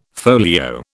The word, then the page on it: folio: British English